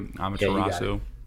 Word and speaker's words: Amaterasu: Is that, am I pronouncing that correctly? Amaterasu. – Yeah, mm-hmm.